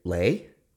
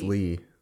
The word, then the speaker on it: Leigh: Like L-E-E? – No, like L-E-I-G-H. – L-E... Lay? I don't think that's Lee. I think that's Lay. – Well, no, that's Drusilla's middle name spelled like that. Drusilla's middle name's Lee.